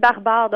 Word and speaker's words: Brbr: Oui, des planètes barbares. Donc, dans le fond, c'est juste, euh, les lettres sont là pour représenter le mot barbare.